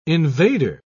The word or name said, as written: invader